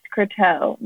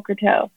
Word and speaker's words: Croteau: It's C-R-O-T-E-A-U, and it's pronounced Croteau, Nicole Croteau. So I always tell people it kind of has a rhythm.